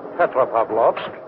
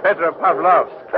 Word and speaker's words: Petropavlovsk: How do you say the name of this port? – Petropavlovsk. It is not hard to say. – Oh, not for you. Petropavlovsk. – That is right.